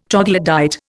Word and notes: troglodyte: Indian

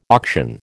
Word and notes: auction: American English

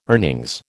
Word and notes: earnings: American English

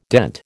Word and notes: dent: American English

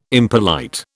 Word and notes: impolite: British English

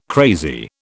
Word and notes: crazy: British English

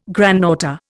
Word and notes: granddaughter: Indian

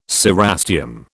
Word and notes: cerastium: British English

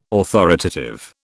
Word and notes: authoritative: British English

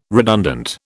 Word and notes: redundant: British English

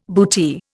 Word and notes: bootee: Indian

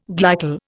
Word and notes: glycol: Indian

